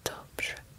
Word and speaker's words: dobrze: You don't hear the R in dobrze. Its function is to change the pronunciation so that the RZ sounds like the S in pleasure. Sure.